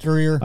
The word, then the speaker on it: Drewer: Uh, Native's band members consisted of Phil Drew, Drewer. Yeah, I'm not sure how to say his name. – Drewer. Uh, Andrew Wetzel, Andrew Whiting, Tyler Sapp, and William Honto.